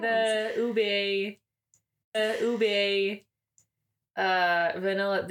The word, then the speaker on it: ube: You've been pronouncing it oob? – What if it is oob? – It's ube. – Rhymes with boob.